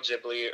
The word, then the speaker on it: Ghibli: Spirited Away is an animated film from Studio Ghibli, or Ghibli, depending on how you pronounce it.